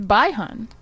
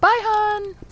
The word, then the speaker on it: Bihan: It's Bihan. Like, Bihan.